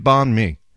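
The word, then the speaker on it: bánh mì: B-A-N-H. A has this little ramp going up. Well, that ramp means banh, like you're going up. And then mi going down is mi. Banh mi.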